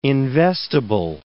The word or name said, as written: investable